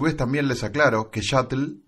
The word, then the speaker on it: sshuttle: Shuttle, que se escribe como lanzadera en inglés, pero con dos S al principio, se pronuncia igual, pero su escritura es distinta.